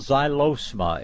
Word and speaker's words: Xylosma: Yeah, look for a vine called Xylosma. It's spelled X-Y-L-O-S-M-A.